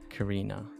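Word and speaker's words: Kirina: Okay, so it's K-I-R-I-N-A. Carina.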